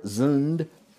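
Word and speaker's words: Zünd: It's by an artist called Robert Zund, Z-U with an umlaut over it. How do you say that? Is it Thank you. Zund. Yes, Robert Zund.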